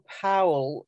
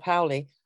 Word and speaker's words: Paole: And there was Arnold Powell. I can't pronounce that myself. P-A-O-L-E. Arnold Powell.